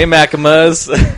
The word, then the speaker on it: Machinima: Hey, Machinimas. – Yeah, no, it's pronounced Machinima.